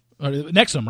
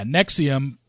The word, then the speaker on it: NXIVM: Nexium, right, is how it pronounced. – But Nexium. Like the heartburn medication. – Yeah, Nexium, but it was NXIVM.